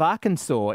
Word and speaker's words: Arkansas: The entire state of Arkansas, is it cat's pajamas or cat's piss? – Cat's piss because it's spelled ARKANSAS. – It's Arkansas.